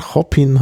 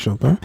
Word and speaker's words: Chopin: Mit einem französischen Namen. Mhm. Das ist nicht falsch ausgesprochen. Also man könnte nicht denken, dass er vielleicht in Wirklichkeit Chopin heißt. Mhm. Man heißt wirklich Chopin. Denn sein Vater war Franzose.